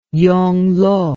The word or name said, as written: yonglo